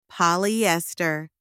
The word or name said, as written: polyester